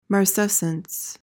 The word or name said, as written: marcescence